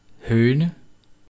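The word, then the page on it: hoene: Danish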